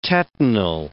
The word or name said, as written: tetanal